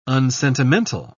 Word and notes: unsentimental: British English

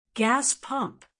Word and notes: gas pump: American English

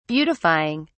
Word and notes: beautifying: British English

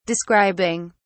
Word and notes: describing: British English

